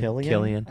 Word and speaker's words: Cillian: Some really great sex scenes with Cillian, or how do you say his name? – Killian. – I would guess. Say Cillian, but it's definitely Killian.